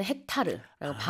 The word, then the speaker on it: 헥타르: 근데 우리는 헥타르라고 발음이 달라요. Okay. Pronunciation이 좀 달라.